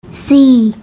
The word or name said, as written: sii